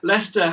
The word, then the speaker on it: Leicester: Leicester has the most random spelling. It catches everyone out. And I couldn't spell it at school. So, yeah, I feel vindicated that I didn't try to say the C. So, like, at the very least, I got that part right, the silent C.